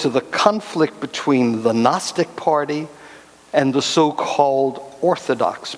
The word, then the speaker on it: Gnostic: The words I have in mind are the Greek words Gnostic and Gnosticism. It's a silent G, which is why you need to put it up there so you can see what it is.